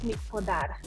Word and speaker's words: Mic-Podar: It's Alex Mic-Podar.